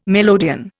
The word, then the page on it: melodeon: Indian